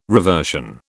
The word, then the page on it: reversion: British English